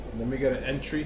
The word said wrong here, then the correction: entree: He goes, entree. I was like, yeah, but it has an extra e.